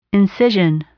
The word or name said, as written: incision